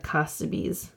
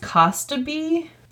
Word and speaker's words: Kostabi: So we're going to start at the beginning of Mark Kostabi's life. Now, it's pronounced Kostabi, but I've also heard people say like Kostabi, but it's spelled like K-O-S-T-A-B-I.